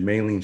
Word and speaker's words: myelin: I think Peter could attest to this, and like certain foods can damage your myelin sheath.